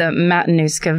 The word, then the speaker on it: Matanuska: Matanuska Valley.